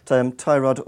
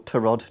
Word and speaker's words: Tyrod: They've got Tyrod or Tarod, depending on how you want to pronounce it.